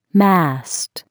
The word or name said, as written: mast